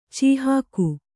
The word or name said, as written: ಚೀಹಾಕು